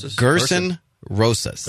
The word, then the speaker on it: Gerson Rosas: This might be a good opportunity for Minnesota to eliminate that and start fresh with how do you pronounce his name again? – Gerson Rosas.